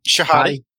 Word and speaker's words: Shihady: Did I say that right? – Shihady. – Shihady. Yeah. My apologies.